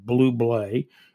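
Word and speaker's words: Bublé: His last name's pronounced Blublé.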